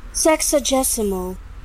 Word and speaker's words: sexagesimal: Sexagesimal. – Sexagesimal. – Oh, sounds sexagesimal. – Nice. – Sexagesimal. Do you know what that is? – Oh. – Shut up. – What a nice word. – Sexagesimal.